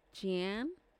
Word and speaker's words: Jean: It's spelled J-E-A-N. Jean? – No, I think that's Jean.